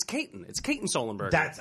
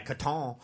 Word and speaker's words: Katon Sullenberger: I put my foot in my mouth and mispronounced his name, and he was like, It's Katon Sullenberger. – I appreciate that. I just did it like a French guy, Katon.